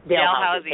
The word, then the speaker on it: Dalhousie: Dalhousie.